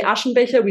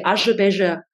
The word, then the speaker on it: Aschebecher: We don't say Aschenbecher, we say Aschebecher.